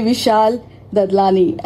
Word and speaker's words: Vishal Dadlani: And this is one and only Vishal Dadlani.